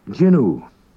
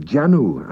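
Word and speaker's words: jannoo: It looks like Janu, and at one time it was Janu, and I have actually heard it pronounced Janu by certain native speakers, but it's now pronounced by everyone Jinu. doing, making.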